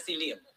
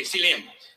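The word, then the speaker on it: selem (צלם): The word image in the Hebrew language in which our Old Testament was originally written is the word selem, S-E-L-E-M. I may not be pronouncing it right, but I'm spelling it right. Okay? And if you ain't Hebrew, you don't know how it's pronounced either. You just going by what somebody told you. But I'm pronouncing, since I be in charge right now, I be pronouncing as selem. Okay, selem. Now, this word selem, S-E-L-E-M, it means copy or counterpart.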